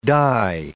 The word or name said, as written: dye